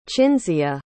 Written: cinzia

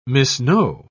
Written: misknow

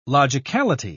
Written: logicality

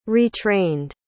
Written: retrained

